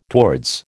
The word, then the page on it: towards: American English